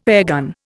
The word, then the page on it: airgun: Indian